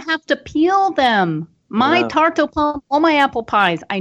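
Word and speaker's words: tarte aux pommes: And I would say this tarte au pomme. How do you pronounce it again? – Tarte au pomme. – Tarte au pomme.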